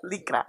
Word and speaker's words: Lycra: Lycra. – Is that what that's how you say in Spanish. – Licra.